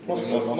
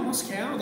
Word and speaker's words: Moscow: Moscow, I don't know how you pronounce that city. – Moscow. – Moscow Dash. It's a very old-school kind of... – Cow. – Very cow, Moscow. That's how the Americans...